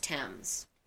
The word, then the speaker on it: Thames: Looks like Thames, pronounced Thames.